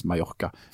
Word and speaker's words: Mallorca: Eh, Mallorca, da, som uttales Mallorca eh, Men eh, det, de, det, var jo en sånn masseturismeplass.